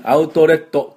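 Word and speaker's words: outlet: ね、アウトレットって言わないようにね。アウトレット。これがポイントです。じゃあ私の後について発音しましょう。アウトレット。アウトレット。あとはね、英語のスペルって OU と書いてアウトと読みますね。オウと読みません。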